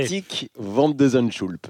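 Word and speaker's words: Botic van de Zandschulp: Botik van de Zandschulp. – Vous l'avez vraiment très bien dit.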